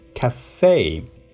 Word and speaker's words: café: 命运这个一呢就不发音，但在这里呢加一飘之后要读成 a 这个音，cafe cafe。